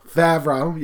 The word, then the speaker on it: Favreau: Favreau, yeah.